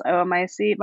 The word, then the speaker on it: oomycete: And my trick for helping to remember how to pronounce oomycete, by the way, is like, what would you say if you like sat on a tack on your chair? Oh, my seat.